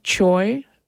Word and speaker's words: Choi: Yi Young Choi, which rhymes with boy, on the clarinet, and John Mayhood on piano.